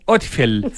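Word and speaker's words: Odfjell: Es como Otfjell. Sí. Eh, porque es como Noruega, ¿no es cierto? De familias noruegas.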